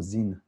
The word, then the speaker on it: Sin: So S-I-N or Zin, as it's sometimes pronounced.